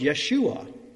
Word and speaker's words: Yeshua: They are the same name pronounced Yeshua, which means, not coincidentally, Yahweh saves.